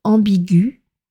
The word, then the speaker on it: ambiguë: Et au féminin, on l'écrit A-M-B-I-G-U-E tréma. Même chose pour les adjectifs aigus et contigus. Donc malgré ce E tréma, la prononciation est la même au masculin et au féminin. On dira donc une phrase ambiguë, une note aiguë, une pièce contiguë et une chambre exiguë.